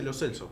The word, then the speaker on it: Lo Celso: El otro día me enteré de que Lo Celso, su apellido, no lo pronuncia como lo pronunciamos todos. Él sí, dice Lo Celso.